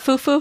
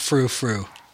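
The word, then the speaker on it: frou-frou: It's not foo foo. No, just not frou frou. – French frou frou. Right? you gotta pronounce the R.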